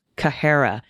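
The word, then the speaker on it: Qahera: The name of that station is pronounced with a beginning CK sound in the throat, not quite a guttural, but farther back in the mouth than an English C, which is mostly a tongue-tip sound, Kahara.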